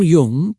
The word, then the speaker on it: Jung: Carl Jung, pronounced Jung, was a wise thinker who lived about 100 years ago and spent his life studying the human mind.